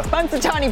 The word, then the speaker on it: Punxsutawney: Punxsutawney Perk.